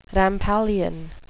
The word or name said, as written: rampallion